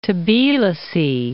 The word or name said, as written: Tbilisi